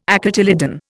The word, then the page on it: acotyledon: Indian